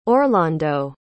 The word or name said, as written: orlondo